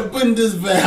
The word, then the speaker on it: Bundeswehr: The Bundeswehr have suffered, bro. You had to pronounce it Bundeswehr.